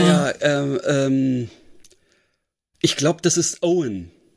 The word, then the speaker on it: Eoin: Also man schreibt in Eoin. – Ja, genau. – Also es ist bestimmt irisch oder sowas. – Ja, genau. Ich glaube, er wird Owen ausgesprochen.